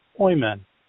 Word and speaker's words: Hoyman: Hoy rhymes with boy and man. Boy, man, Hoyman.